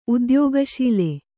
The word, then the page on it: ಉದ್ಯೋಗಶೀಲ: udyōgaśila